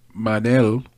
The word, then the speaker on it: Manoel: Manel, which sounds like Manuel, but it's spelled M-A-N-O-E-L on Tapology.